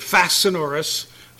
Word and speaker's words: facinorous: So now you have a new English word, fascinorous. Sorry, Linda. Atrociously wicked. Can I spell it for you, fascinorous? F-A-C-I-N-O-R-O-U-S.